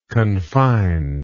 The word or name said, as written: confinedness